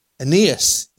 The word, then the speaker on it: Aeneas: Aeneas, yes, who had been bedfast for 8 years and was paralyzed.